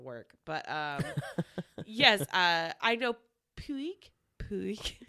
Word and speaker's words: Puig: Did you say Puig? There's a Puig. – That's Puig. Like, it's spelt that way, Puig.